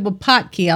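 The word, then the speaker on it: Wupatki: And Mark and I both said Wapatki, although I didn't pronounce it correctly. I said Telepaki and Wapaki, blah, blah, blah.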